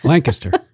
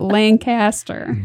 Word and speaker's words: Lancaster: You got to say it right. – Lancaster. – Not Lancaster. – Lancaster. – That's New York Lancaster.